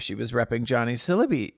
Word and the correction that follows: Sibley: How do you say his last name? – Sibley.